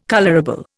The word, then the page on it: colourable: Indian